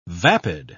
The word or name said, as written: vapid